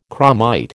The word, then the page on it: chromite: American English